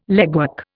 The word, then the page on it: legwork: Indian